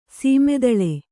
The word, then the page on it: ಸಮತಳ: sama taḷa